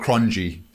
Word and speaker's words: Cronje: Cronje and all of that. Yeah, his surname is spelled Cronje, C-R-O-N-J-E, if you want to know. – Ah, and that's pronounced Cronje.